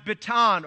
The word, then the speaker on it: bitan: It's interesting to note that the word, of the meaning for bitterness can be traced to a word called that's pronounced bitan, or B-I-T-A-N.